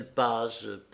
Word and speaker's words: Paz: ce capitaine Paz, dont le nom se prononce comme il est écrit.